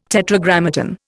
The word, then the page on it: Tetragrammaton: Indian